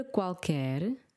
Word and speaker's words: qualquer: Qualquer. Q-U-A, pronunciamos o U. Qua. Q-U-E, não pronunciamos o U.